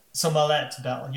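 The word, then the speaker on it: Smollett-Bell: Is that how you pronounce it? – I would say Smollett Bell. – Smollett Bell, yes.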